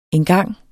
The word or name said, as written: engang